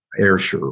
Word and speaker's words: Ayrshire: Um, I know that you were born, as we discussed in Ardrossan, and Ayrshire.